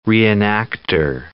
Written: reenactor

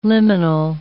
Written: liminal

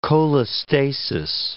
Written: cholestasis

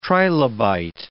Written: trilobite

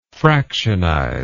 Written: fractionize

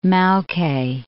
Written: Maoke Mountains

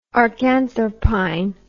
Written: archanthropine

